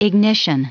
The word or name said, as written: ignition